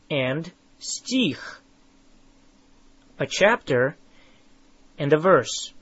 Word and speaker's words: Стих: Now, how do you say a verse? Стих. Стих. Say их. Их. Тих. Тих. Now add an S at the front of it. Стих. Стих.